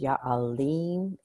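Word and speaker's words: Ya Aleem: And we used Ya'alim, A-L-E-E-M is how it's pronounced.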